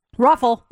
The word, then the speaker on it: ROFL: What about R-O-F-L? – Ruffle.